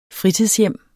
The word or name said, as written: fritidshjem